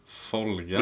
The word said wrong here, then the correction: Volga: Volga. – Ja, är det F? Uttalar man vet med F? – Nej, Volga. – Folga. – Volga som är Viktor. Man säger inte Viktor.